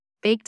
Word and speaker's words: B4K3: Today, we have another guest episode with Baked Deliveries. Is it Baked Deliveries? Is it Beef? What? How do I pronounce it? It is. It is. It's Bake. I always introduce myself as Bake, spelled B4K3.